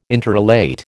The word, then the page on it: interrelate: American English